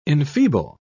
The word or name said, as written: enfeeble